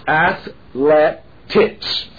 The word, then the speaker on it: athletics: No, there is no A in there. It's athletics, three syllables. Don't put a fourth in, all right?